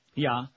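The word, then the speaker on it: ja: How do you say yes in Dutch? – Yeah. – Yeah. Really? – Yeah. – They weren't just making that up? – No, I was not. Nay. Nay is no.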